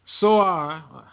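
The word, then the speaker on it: Tzor: Soar, they pronounce it like I said, some will look at it and say it's Tyre, but Soar has built herself a stronghold and heaped up silver like dust and gold like the mire of the streets.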